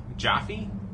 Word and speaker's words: Jaffe: It's J-A-F-F-E. It's Jaffe? – I'd say... – Jaffe? – Yeah, Jaffe.